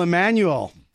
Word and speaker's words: Emanuel: co-founder, CEO of Extreme Music, Russel Emanuel. Did I say that right? – You said it right.